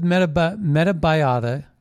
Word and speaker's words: Metabiota: So, along with Metabiota, that's, well, I'll spell that for you, Meta, M-E-T-A, Biota, B-I-O-T-A,